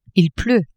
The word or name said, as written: il pleut